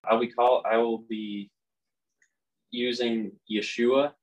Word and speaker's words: Yeshua: and so when a Hebrew says Yeshua's name really fast, it just sounds like Yeshua because it's pronounced Yeshua. The middle ha is kind of really, really missable in there when you say it really fast like that.